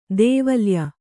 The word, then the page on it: ಧಾವಲ್ಯ: dhāvalya